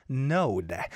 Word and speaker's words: NOHD: Today's song was Salty by Brostone, Chong Yong Hoon, Haeun, and NOHD, but I think this guy's name is pronounced as Node.